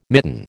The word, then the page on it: mitten: American English